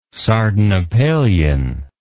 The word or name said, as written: sardanapalian